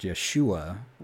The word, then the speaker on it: Yeshua: You bring up another question, which is, if Jesus is the name of God and it's pronounced Yeshua, why does it translate into English as Joshua?